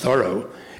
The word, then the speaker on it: Thoreau: Thoreau, now it's pronounced Thoreau, was very concerned that the founding fathers had committed the original sin.